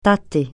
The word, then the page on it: tâter: francais